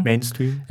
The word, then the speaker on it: Mainstream: Mainstream? Ja. Ja, ich hab's außerdem die ganze Zeit falsch gesagt. Ich hab Mame, Mame gesagt. Ich so, hä? Das ist aber süß. So heißt das ja nicht.